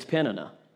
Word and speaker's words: Peninnah: Just to let you know, I actually looked up how to pronounce Peninnah. That's right. It's Peninnah.